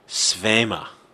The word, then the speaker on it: Svema: Because everyone here at the FPP are home-rolled Shmina film. – Yeah. – Actual pronunciation is Svema.